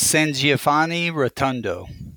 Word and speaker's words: San Giovanni Rotondo: how do you pronounce the church? – It's San Giovanni Rotondo.